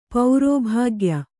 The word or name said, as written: ಪೌರೋಭಾಗ್ಯ